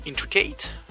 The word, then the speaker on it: Intricate: C'est Intricate, donc ça s'écrit Intric 8, hein, donc je le dis en anglais, Intricate, Ah oui, hate.